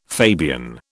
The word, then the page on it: Fabian: British English